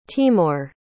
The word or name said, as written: Timor